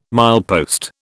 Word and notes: milepost: British English

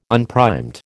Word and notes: unprimed: American English

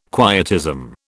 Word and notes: quietism: British English

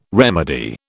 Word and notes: remedy: British English

